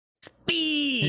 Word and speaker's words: speen: Speen. – You keep saying it like it's a normal word. – S-P-E-E-N. Yes. That's how it's pronounced.